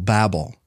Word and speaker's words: Babel: Tower of Babel. Not Bible, Babel. – Babel. – B-A-B-E-L, Babel.